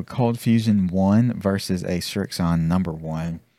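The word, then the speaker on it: Srixon: Is that how you say it? – Strixon. Mm-hmm. Sure is.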